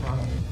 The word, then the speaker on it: Toronto: Toronto. – Please don't pronounce the T's.